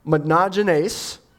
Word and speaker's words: monogenes: So monogenes, so the first part of that, mono, M-O-N-O, means one or only. And then the second part, which is pronounced genes, but it's spelled genes, G-E-N-E-S, what does that typically mean?